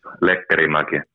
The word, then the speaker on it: Lekkerimäki: So it's Lekkerimäki. – There it is. – I'm not sure if Swedish people do it that way. I'm quite sure it must be close. And at least in Finnish, it's a Finnish name.